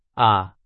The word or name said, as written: 啊